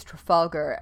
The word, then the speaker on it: Trafalgar: I think it is Trafalgar, and I have only heard it pronounced by someone with an accent that would have swallowed the R.